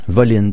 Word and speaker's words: Volin: Voline. Donc ça a l'air que ça se prononce comme ça, mais ça s'écrit Volin. – C'est V-O-L-I-N.